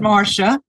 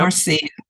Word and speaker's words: Marcia: And Marcia, you spell it M-A-R-C-I-A. – C-I-A. Yep. Yeah. And I pronounce it Marcia, not yep. Marcia.